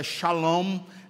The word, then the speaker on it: Shalom: porque a palavra Salém, que a gente pronuncia assim, mas na verdade é Shalom, essa palavra é paz.